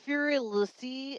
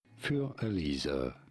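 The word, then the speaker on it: Für Elise: It's not Furelise. I don't even know if that's how it's pronounced. I'm saying it like I know. – I know it's not Elsie. Yeah, it's Elise, definitely. – Für Elisa. Pretty straightforward, once you know.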